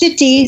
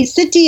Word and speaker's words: Sitti: It's Siti. Siti in Arabic means my grandmother.